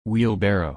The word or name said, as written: wheelbarrow